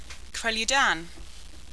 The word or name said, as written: kreludan